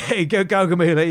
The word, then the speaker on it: Gaugamela: But first, he's got to get through Gaugamela. – That's right, yeah. First of all... – Did I pronounce that right? – Yeah, Gaugamela, yeah.